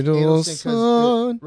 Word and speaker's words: Adelson: Adelson rhymes with dreidel, then.